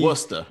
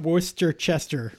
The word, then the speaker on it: Worcester: Like, normally, but it is Worcester. Right? – Worcester is right. Worcester is correct. It's not Worcester-Chester or whatever it's spelled like.